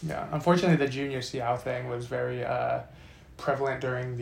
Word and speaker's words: Seau: I would say the same thing that happened with, I don't know how to pronounce his name, Junior Seau? – I believe it's Seau, yeah.